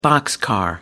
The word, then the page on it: boxcar: American English